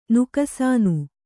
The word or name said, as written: ನುಕಸಾನು